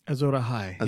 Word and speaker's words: Azor Ahai: Azor Ahai? – Azor Ahai.